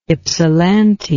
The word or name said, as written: ypsilanti